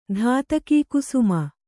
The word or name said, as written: ಧಾತಕೀಕುಸುಮ